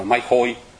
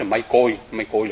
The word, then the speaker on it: mai côi: Bây giờ quý vị đọc ấy, thì người ta đọc là mái khôi, nhưng mà đúng ra mình phải nói là mái côi là cái chữ nguyên thủy của nó, đúng.